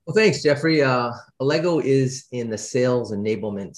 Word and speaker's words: Allego: Our next guest is co-founder and president of Allegro.